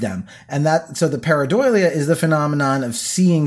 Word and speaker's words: pareidolia: Like you talk, have you ever heard of a phenomenon called, I think it's pronounced pareidolia or pareidoia?